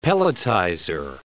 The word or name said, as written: pelletizer